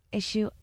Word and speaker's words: Issuu: Issue. It's spelled ISSUU.